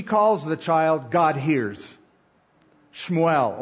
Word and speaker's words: Shmuel: Verse 20, it came to pass in the process of time that Hannah conceived and bore a son and called his name Shmuel. That's the Hebrew pronunciation. Samuel, as we say it, saying, because I have asked for him from the Lord.